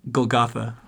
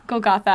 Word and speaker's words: Golgotha: Golgotha. – Golgotha.